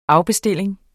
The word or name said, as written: afbestilling